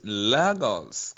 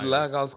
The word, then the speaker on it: Lagos: Lagos. Call it Lagos.